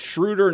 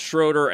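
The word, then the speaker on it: Schröder: As far as Dennis Schroeder stepping in for Russell Westbrook, and it's Schroeder, not Schroeder, and I know I've been mispronouncing that, and I've heard everybody say it both ways.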